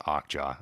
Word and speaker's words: Okja: apparently it's pronounced Okja. I learned that recently.